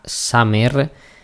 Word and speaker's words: Summer: Y calculo que lo que queda de programa hoy aquí en Intangible 23 vamos a centrarnos en la banda Summer. Se escribe igual que verano en inglés, así que yo lo pronuncio así.